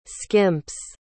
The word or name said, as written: skimps